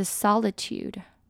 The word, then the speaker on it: solitude: OK, 下一个我们来看的是 solitude, only 是发 R 的音，重音在前面。